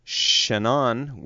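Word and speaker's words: Shanon: Edward Griffin, the author of The Creature from Jekyll Island on the Federal Reserve, and also Professor Benny Shannon, which I've just learned is the proper pronunciation of his last name, from the Hebrew University of Jerusalem, Tel Aviv, Israel.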